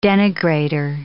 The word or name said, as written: denigrator